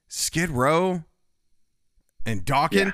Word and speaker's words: Dokken: and it is pronounced Dokken. They confirmed.